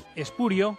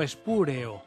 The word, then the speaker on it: espurio: Diremos espurio con diptongo y no espúreo. Repito, la fórmula esdrújula, espúreo, o la forma esdrújula, es incorrecta.